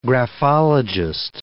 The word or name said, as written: graphologist